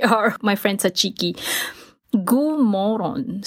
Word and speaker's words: god morgon: So you spell G-O-D, first word, morgon, M-O-R-G-O-N. It's not M-O-R-O-N. That is moron. You moron. So it's pronounced somewhat like moron, but it doesn't mean moron. So you say god morgon.